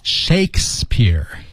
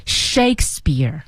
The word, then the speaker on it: Shakespeare: Shakespeare. – Shakespeare.